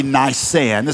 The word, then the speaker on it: Nisan: That's actually Nisan, is how you say that.